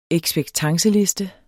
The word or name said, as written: ekspektanceliste